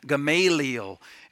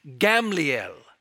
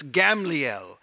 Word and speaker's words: Gamliel: And in Hebrew, it's actually pronounced Gamliel. In fact, that's how the Geneva Bible in English printed it. The second A wasn't even there. So it's Gamliel.